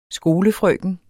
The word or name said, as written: skolefrøken